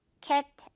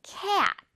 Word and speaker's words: cat: 선생님은 정말 좋아하는데 고양이도 고무줄 발음입니다. 그냥 단순하게 cat 아니겠죠. cat. 입을 고무줄처럼 늘어뜨려야 돼요.